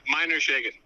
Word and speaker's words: Meinershagen: Meinershagen.